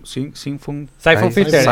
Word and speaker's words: Siphon Filter: El Symphon, Siphon Filter, el que vos habías dicho.